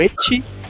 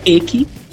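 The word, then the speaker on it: ecchi: Bom, dentro do shonen também a gente pode citar o Echi, dessa vez eu acho que a pronúncia está correta. – Que não é Eki.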